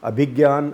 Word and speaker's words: Abhigyan: This story was broken, in fact, yesterday by Abhigyan. I think that is the correct pronunciation of his name, although it's spelt like Abhijnan, but Abhigyan raged at the diplomat who spotted this story that an American destroyer had come into India's EEZ, apparently without informing India.